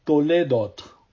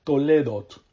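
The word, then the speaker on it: Toledot (תולדות): Y aquí llegamos a la próxima sección, o como se dice en el hebreo, en la Biblia, a Toledot, Toledot, así como les estoy pronunciando, Toledot, con una T al final.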